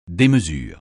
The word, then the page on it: démesure: français